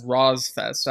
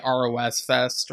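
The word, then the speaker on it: Rosfest: Rozfest, and in parentheses, he says, by the way, you can pronounce it as Rozfest. I guess because on the episode, I was saying ROS Fest or something, but yeah.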